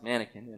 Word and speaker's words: manakin: There's a bird in the Amazon rainforest called a manakin. It's spelled differently than like a mannequin, but it's pronounced mannequin.